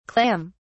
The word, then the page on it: clam(s): British English